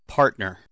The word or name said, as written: partner